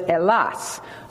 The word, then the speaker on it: Hellas: They know that you and I do as Americans, but they call it Elas, and it's spelled H E L L A S. The H is silent. You Americans, you know, would say Hellas, but it's Elas.